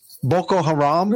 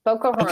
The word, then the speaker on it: Boko Haram: First of all, am I pronouncing it right? – Because you are, yes. – Boko Haram? – Mm-hmm. Boko Haram, okay.